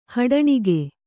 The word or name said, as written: ಹಡಣಿಗೆ